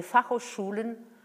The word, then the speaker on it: Fachhochschulen: Alors là, je vais le prononcer en allemand, tenez-vous bien, les Fachhochschulen euh, d'Allemagne.